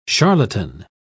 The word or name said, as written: charlatan